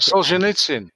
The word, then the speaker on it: Solzhenitsyn: It's from a Russian author called Alexander Solzhenitsyn. I'm sure I'm not pronouncing that. – Solzhenitsyn? – Yes, yes.